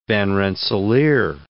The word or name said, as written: Van Rensselaer